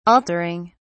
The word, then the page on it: altering: British English